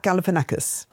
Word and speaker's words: Galifianakis: Is it Galifianakis? – I think that's it. Yeah, Zach Galifianakis.